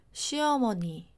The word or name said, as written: 시어머니